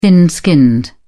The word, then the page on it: thin-skinned: British English